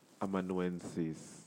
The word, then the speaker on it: amanuensis: Amanuensis. Okay, let me say that again amanuensis. It's spelled A M A N U E N S I S.